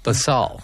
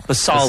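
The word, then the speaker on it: Basalt: the Citroen Bastal. How do I say that? – Basal. – Basal. There we go.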